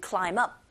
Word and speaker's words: climb: Climb의 b 사운드 묵음인 거 당연히 아실 테고, climb의 b 소리 안 나니까 climb up이라고 해서 사운드 자음과 up의 어라는 모음이 연결돼서 들리기 때문에 climb up 이렇게 소리가 들리게 됩니다.